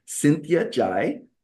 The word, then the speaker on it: Cynthia Zhai: And I am so excited to have today Cynthia Zhai.